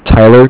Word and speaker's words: Tyler: My name is Tyler, T-Y-L-E-R, and I'm calling from Cardiff. It's spelled C-A-R-D-I-F-F, as in Frank.